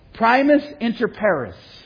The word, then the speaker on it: primus inter pares: And the phrase is pronounced primus inter pares, which means first among equals.